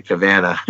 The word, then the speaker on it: Cavanaugh: He was like, "It's actually Cavanaugh."